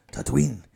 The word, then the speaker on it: Tatooine: Tatooine.